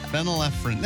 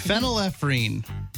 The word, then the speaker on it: phenylephrine: Fulnaephrine. Phenylephrine. That's it. Phenylephrine. Sorry. – I love her her movies and books. – Phenylephrine. That's how you say it.